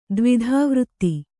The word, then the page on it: ದ್ವಿಧಾವೃತ್ತಿ: dvidhā vřtti